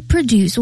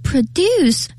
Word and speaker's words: produce: 后面的这个 local produce，我们知道 produce 像我的这种发音，重音在后面的话，通常它是一个动词。 – 哎，动词，是的啊。如果呢这个词它念成了 produce，它变成了名词，表示一个农产品。